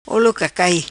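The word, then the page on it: Olo Cacai: patua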